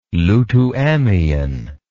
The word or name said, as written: Lutuamian